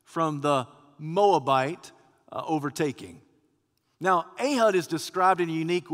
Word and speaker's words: Ehud: Now, probably the best way to pronounce it in Hebrew is Ehud. But here in Alabama, it's just Ehud.